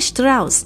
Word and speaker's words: Strauss: 那 Levi Strauss 这个 Strauss 我也特别用德文发音，为什么呢？因为呃，Levi Strauss 他其实是土生土长的德国人，是在移民美国之后才发明了牛仔裤，那他也才改名叫做 Levi 的。